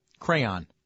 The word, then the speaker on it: crayon: Do you say crayon or crayon? – Crayon. 2 syllables.